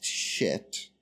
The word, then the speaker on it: Scheat: Also the location of a fixed star spelled S-C-H-E-A-T and pronounced shit.